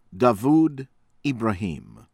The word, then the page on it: Dawood Ibrahim: American English; from India